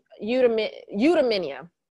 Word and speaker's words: eudaimonia: It's eudaimonia. Eudaimonia. It's spelled E-U-D-A-I-M-O-N-I-A.